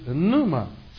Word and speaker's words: pneuma: And the Greek word is pneuma, spelled with a P at the beginning. P-N-E-U-M-A. Pneuma.